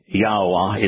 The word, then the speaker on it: Yahweh: If you look at the way that the Hebrew vowels, Yod, He, and Wah, are pronounced in some 10,000 words that Yahweh used to comprise his Torah, prophets, and Psalms, you'll find that Yahweh is the only applicable way to pronounce those three vowels.